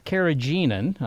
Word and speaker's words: carrageenan: Well, carrageenan, I'm not sure I pronounced that correctly. – You did.